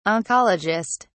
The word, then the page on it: oncologist: British English